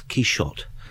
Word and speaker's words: Quixote: Cervantes, coming from north of Madrid, wouldn't have used the guttural J or Jota at the time of writing Don Quixote, Quixote, or whatever it is. It's much more likely he himself would have pronounced the Don's name as Quixote.